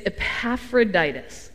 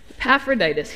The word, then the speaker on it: Epaphroditus: which is a good thing because he has a really hard name to pronounce. It's Epaphroditus. Epaphroditus.